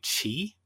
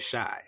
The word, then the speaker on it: Chi: Before I talk about the singles, I just want to say, growing up, knowing Chicago is pronounced Chicago, I would see this and I would just think chi. Like C-H-I, but then it's like, no, the Shy. And I'm like, well, Shy is spelled S-H-Y.